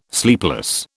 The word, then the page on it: sleepless: British English